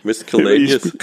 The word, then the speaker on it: Miscellaneous: Mischievous. Sag's auf Deutsch. – Miscellaneous.